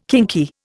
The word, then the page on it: kinky: Indian